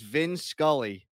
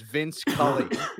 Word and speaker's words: Vin Scully: It was Vin Scully, and people thought his name was Vince Cully.